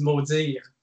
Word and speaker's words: Maudiir: But the real way to say it in French, of course, is Maudiir.